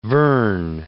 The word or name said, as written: Verne